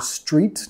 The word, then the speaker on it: street: Cuidado con la palabra street. No quieres meter una sílaba extra. No quieres decir e street, ¿ok? Más street. Empezando directamente con la S, street.